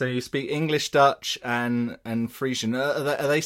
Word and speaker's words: Frisian: Yeah, I'm talking Frisian.